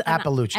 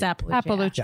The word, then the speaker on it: Iapalucci: It's Appalucci. – Everyone always does that. – It's Appalucci. – It's Appalucci. Yeah. But everyone thinks it's Lapalucci.